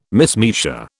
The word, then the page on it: mismeasure: British English